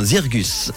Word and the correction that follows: Zirkuz: Zircus. Zircus, voilà.